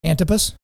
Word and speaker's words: Antipas: Antipas.